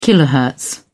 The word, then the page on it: kilohertz: British English